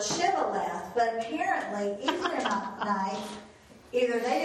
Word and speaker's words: Shibboleth: And they would say what? – Sibboleth. – And so it says, for he could not pronounce it correctly.